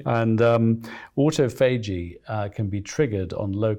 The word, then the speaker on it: autophagy: It's called autophagy, means self-eating, or the Americans call it autophagy.